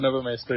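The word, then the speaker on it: Nové Město: Is that the correct pronunciation? – Yeah, it's close enough. They just call it Nové Mesto, yeah.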